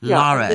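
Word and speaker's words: Laragh: It's L-A-R-A-G-H. Lara.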